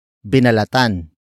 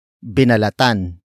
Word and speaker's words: binalatan: Binalatan ang mansanas kanina. Binalatan ang mansanas kanina. The first word is binalatan. Binalatan. Binalatan. Binalatan is spelled as B I N A L A T A N.